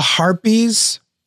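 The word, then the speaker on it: Harpys: So it's the Harpies. – Harpies, I'm sorry. Not the Heartbeats. – Right, yeah. – Harpies? – Harpies. – Okay, they just say it like it sounds like Heartbeats.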